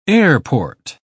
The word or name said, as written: airport